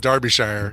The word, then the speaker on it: Derbyshire: It's actually, believe it or not, pronounced Derbyshire. – Derbyshire. – Even though it looks like it should be Derbyshire. It's Derbyshire.